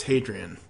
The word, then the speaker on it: Hadrian: And it's basically Adrian with an H sound at the beginning. So it's Hadrian.